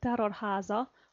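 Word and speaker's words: Terror Háza: You speak Hungarian, how do you say this? – You call it Terror Haza, Andrássy Road. – Okay, Andrássy Road. Andrássy? So, Andrássy Road, Terror Haza.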